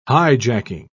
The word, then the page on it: hijacking: British English